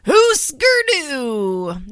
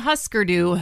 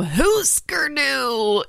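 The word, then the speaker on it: Hüsker Dü: Next up is a band that I recently learned I was mispronouncing. Huskerdu, not Huskerdu, Huskerdu is up next.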